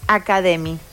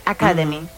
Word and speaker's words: Academy: Las propuestas educativas inician durante el mes de mayo, forman parte de la iniciativa de la Academia Ex Academy. Academy. Lo pronuncié mal.